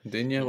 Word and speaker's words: Digne: Digne, we'll say, because that's how it's pronounced.